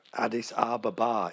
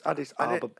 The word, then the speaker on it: Addis Ababa: Addis Ababa, it's pronounced. – Is it? – Yes, Addis Ababa.